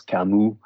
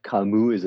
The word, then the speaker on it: Camus: It's with an S, but you don't pronounce the S. Camus. Albert Camus is a great inspiration.